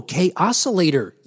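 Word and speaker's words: Kaossilator: But also, Korg is doing the same for their, and I'm going to say they pronounce this the K-Oscillator app. K-Oscillator app? – Ooh, yeah, sounds like chaos to me, yeah. – Oh, K-Oscillator, yes!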